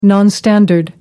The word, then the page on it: nonstandard: British English